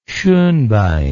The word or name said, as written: schonbein